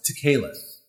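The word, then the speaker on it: tekelet (תכלת): The word is spelled T-E-K-E-L-E-T. And it's pronounced tekelet, tekelet. The definition is the color blue, but it covers the spectrum between red through deep purple to blue.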